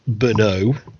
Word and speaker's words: Brno: It is, of course, pronounced Brno in the Czech Republic, or Czechia, as we're now meant to call it for some reason.